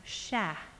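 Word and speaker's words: cher: We have a term of endearment that is pronounced sha. Sha. Can you say that? Sha. Now let's drag it a little. Sha. It sounds like it ends in, like, with the A in at the end, and it means dear or precious.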